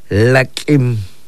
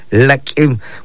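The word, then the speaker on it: lak'in: Este, lo pronunciamos de esta manera, laquim, laquim.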